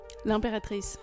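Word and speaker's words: L'Impératrice: This is another track, Lun by L'Imperatrice.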